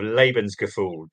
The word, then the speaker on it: Lebensgefühl: What did you think of my pronunciation of Lebensgefühl? Did I get that right? – Yes, you did it right. 100%.